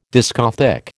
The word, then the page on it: discothèque: American English